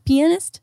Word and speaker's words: pianist: Isn't it? Penis? Isn't that how you say it? Pianist? – No, it's pianist.